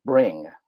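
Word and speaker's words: Bringg: And there was a $30 million round that was raised for a startup company called Bring, which is spelled like it sounds, but with two G's on the end.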